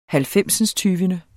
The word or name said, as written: halvfemsindstyvende